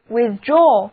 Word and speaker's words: withdraw: 接下来我们在 with 后面加上 draw 这个单词 D-R-A-W draw 它的意思是拉或者收，所以 withdraw W-I-T-H-D-R-A-W withdraw 的意思就是收回或者撤回，字母组合 A-W 读作长的 O withdraw withdraw 比方说军队撤退了，就可以说 The army withdrew.